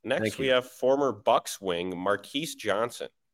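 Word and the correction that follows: Marques Johnson: Marcus Johnson. I didn't look at the pronunciation.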